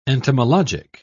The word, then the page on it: entomologic: British English